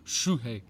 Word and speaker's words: Shuhei: Shoe or su? – Shu. – Shuhei. – Like as in a shoe.